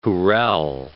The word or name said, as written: jurel